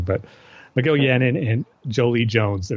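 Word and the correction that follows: Joëlle Jones: Joel Jones, you mean? – What did I say? – Jolie. – Okay, Joel.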